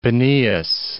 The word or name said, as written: Peneus